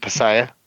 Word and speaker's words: Piasa: I think it's pronounced Piasa. That's how I'm pronouncing it. It's a P-I-A-S-A. Piasa.